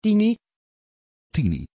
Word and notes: tini: Dutch

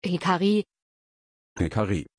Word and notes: hikari: German

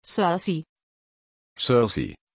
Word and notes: cersei: German

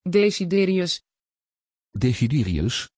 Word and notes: desiderius: Dutch

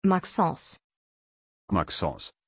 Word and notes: Maxence: German

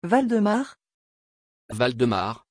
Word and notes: valdemar: French

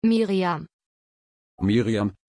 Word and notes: Myriam: German

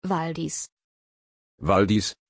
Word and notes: valdis: German

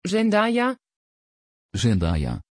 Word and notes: Zendaya: Dutch

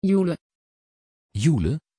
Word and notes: joëlle: Dutch

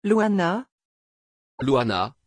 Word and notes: luana: French

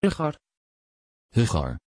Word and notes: Rhaegar: Dutch

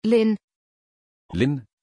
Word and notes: Lynn: German

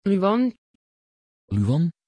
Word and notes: luan: Dutch

